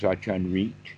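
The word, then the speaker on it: Ajahn Rit: In fact, they get the English out of the Thai, and the English winds up being very strange with an R-I-T-T-H-I is how they get it into the English, but his name is pronounced Achan Rit with a rising tone, Rit.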